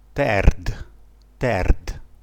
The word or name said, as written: terd